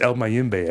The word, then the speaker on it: El Mayimbe: When Jeffrey Bright played Dominican, most ignorantly, by the way, that's where El Mayimbe comes from because he said it incorrectly in the movie. And it's like, it's El Mayimbe, asshole.